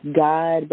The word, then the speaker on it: Gaad: I was a part of, it's pronounced God, but it's spelled G-A-A-D.